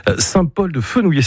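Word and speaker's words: Saint-Paul-de-Fenouillet: Perpignan, vous irez un petit peu plus tard parce que c'est un peu la grande ville pour vous, mais Saint-Paul-de-Fenouillé, c'est comme ça que vous le prononcez. – Oui, c'est ça.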